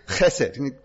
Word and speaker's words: chesed: It's got a bit of a ch attached to it, even though it's spelled with an H. Chesed.